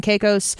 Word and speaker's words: Caicos: Ventura and male escorts traveled through Los Angeles, Miami, New York, Atlanta, the Turks and Caicos.